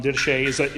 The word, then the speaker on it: Didache: The Didache, or Didache, I always pronounce it wrong, Didache, is a Christian text that was written in the first century, around 90 AD, shortly after the Bible would have been completed.